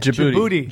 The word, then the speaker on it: Djibouti: Djibouti. – Djibouti.